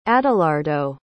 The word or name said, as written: adalardo